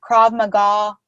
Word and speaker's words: Krav Maga: There was a Krav Maga. How do you say it? – Krav Maga room.